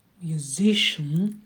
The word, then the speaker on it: musician: Musician, на зи падает ударение. Musician и grandfather.